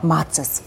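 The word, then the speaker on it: Macis: Ansonsten ist es wirklich, glaube ich, also ja, es ist in aller Munde und es wird größtenteils falsch ausgesprochen. Mhm. Aber deswegen Macy's.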